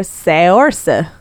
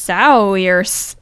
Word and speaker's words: Saoirse: Yeah, I was like, if we named our daughter that, people would be calling her Saoirse. – S- Sowers.